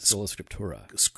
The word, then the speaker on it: Sola Scriptura: You don't say it like Sola a Spaniard. – How do you say it? – Sola Scriptura. – Sola Scriptura. – Sola Scriptura. – I don't know how to. That's the way I say my R's.